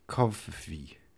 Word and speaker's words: Covfefe: It's spelt, by the way, C-O-V-F-E-F-E. Kvofifi.